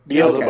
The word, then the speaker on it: Beelzebub: Beelzebub.